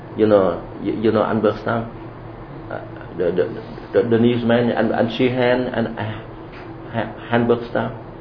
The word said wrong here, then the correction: Halberstam: Halberstam. – Halberstam. You know my pronunciation.